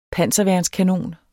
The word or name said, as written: panserværnskanon